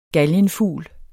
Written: galgenfugl

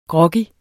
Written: groggy

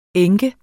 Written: enke

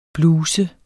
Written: bluses

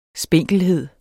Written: spinkelhed

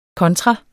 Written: kontra

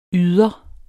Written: yder

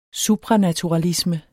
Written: supranaturalisme

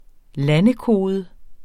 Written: landekode

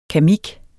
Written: kamik

